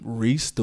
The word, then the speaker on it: Reis: Did you know that my name, Reis, like the same, I spell my name R-E-I-S.